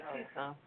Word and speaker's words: Tucson: Tucson, Arizona? – Mm-hmm. – It's called Pima. Is it Tucson? – Yeah, Tucson. – Okay, so the C must be silent.